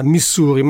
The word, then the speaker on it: Missouri: Yeah, yeah, Era Missouri, che dovrebbe essere il modo in cui più correttamente gli americani chiamano lo stato che noi conosciamo più semplicemente pronunciandolo all'italiana Missouri, ma soprattutto era un brano incluso nel nuovo album di Eddie Nainvi chiamato Capricorn.